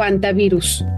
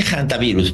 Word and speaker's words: hantavirus: ¿Se pronuncia así, hantavirus o antivirus? – En general se dice hantavirus, porque hantavirus. Así se dice internacionalmente. – Como mi H, ¿no? Mi H también suena.